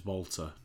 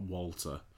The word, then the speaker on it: Walter: Well, the NXT people calling him Walter because they, you know, I'm sure W's are pronounced as V's, aren't they, in German? But that's what they said at Progress. It was Volta, not Walter.